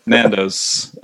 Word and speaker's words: Nando's: Nandos.